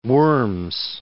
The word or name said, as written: Worms